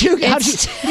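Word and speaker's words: cheugy: Is it choogy? – It's choogy. You knew it was choogy before I even said it.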